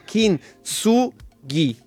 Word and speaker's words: kintsugi: La pronuncia è Kintsugi. Kintsugi.